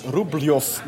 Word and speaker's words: Rublev: Oh, dat heb ik niet gezien iemand die eh, blijkbaar heel gespecialiseerd was in het uitspreken van Russische namen, die, eh, die gaf het heel in detail toe waarom het als Rubljov moet worden uitgesproken.